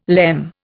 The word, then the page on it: lemme: Indian